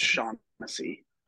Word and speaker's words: Shaughnessy: It's pronounced Shaughnessy. – Yeah, why is there a G in an H? – Shaughnessy.